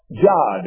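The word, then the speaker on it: Yod: It is pronounced Jod. It is a Jod in Hebrew.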